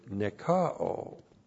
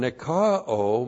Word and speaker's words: nikao: It is a substantival participle of the verb nikao. N-I-K-A long O. And nikao means to be victorious, to conquer.